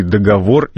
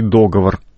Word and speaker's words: договор: Согласно указанным словарям, теперь кофе можно произносить как «вкусное кофе», то есть в среднем роде, можно говорить и «договор», и «договор». Продолжит тему Лиля Пальвелева.